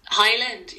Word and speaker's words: Hyland: This week on the Drama Graduate Podcast, we have Ella Lily Hyland. – I've never heard you say your second name before. Hyland. – Hyland, yeah.